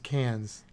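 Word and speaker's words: Cairns: Behind Cannes. I think it's pronounced Cannes, like the one in France.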